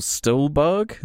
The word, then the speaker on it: Stuhlbarg: God, Michael Stuhlbarg, is that how you say it? – Yeah.